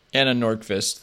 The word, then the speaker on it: Anna Nordqvist: Anna Nordqvist, though.